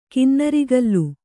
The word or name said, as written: ಕಿನ್ನರಿಗಲ್